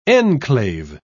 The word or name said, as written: enclave